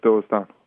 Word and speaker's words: Doylestown: Now, you're from Doylestown, Pennsylvania. Am I saying that right? – Yep, yep, Doylestown.